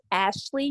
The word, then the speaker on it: Ashli: My name is Ashli. You spell it A-S-H-L-I, last name Cotton, C-O-T-T-O-N.